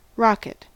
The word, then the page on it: rocket: American English